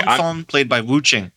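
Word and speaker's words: Leng Feng: I think it's Fong. – God damn it. Let me keep going. – Yeah, it's pronounced Long Fong, played by Wu Jing.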